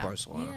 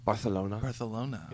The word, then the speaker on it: Barcelona: I love Barcelona. Yeah. Great city. – Mispronouncing it. – Barcelona. Yeah, sorry. – I like that you guys get like a little bit of wiggle when you say that.